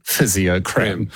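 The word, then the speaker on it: Fisiocrem: they choose to shun all traditional spelling because it's F-I-S-I-O instead of P-H. – Yeah. – And then Crem, C-R-E-M. – Cream. – So instead of cream. Yeah. So what is it? It's a cream for physios. Yeah. How do we say it? Physiocrem.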